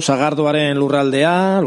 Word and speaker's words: Sagardo Haren Lurraldea: ¿Formáis parte del proyecto Sagardo Haren Lurraldea, lo digo bien así? – Sí, muy bien, eso es. Sí, Sagardo Haren Lurraldea, digamos que es como una marca turística que aglutina un poquito pues todo lo que está alrededor de la sidra, ¿no?